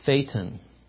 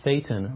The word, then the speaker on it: Thetan: And I have to insert at this point that perhaps it's a mere coincidence that the word Thetan rhymes with Satan, whom we studied about this morning.